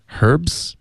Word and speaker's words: herbs: 而且有一些英文的字来自法语的时候呢，就它有可能就会带法语的发音，就比如 Herbs。